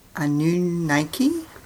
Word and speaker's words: Anunnaki: Anunnaki? – Yep.